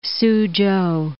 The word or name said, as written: Suzhou